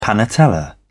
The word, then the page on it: panatella: British English